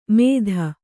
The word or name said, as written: ಮೇದ